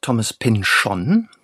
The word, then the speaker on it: Thomas Pynchon: Also man sagt Thomas Pynchon.